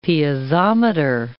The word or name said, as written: piezometer